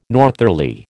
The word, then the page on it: northerly: American English